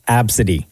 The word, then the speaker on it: Abcde: A, B, C, D, E. And they pronounce it Absidy.